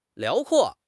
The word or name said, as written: 辽阔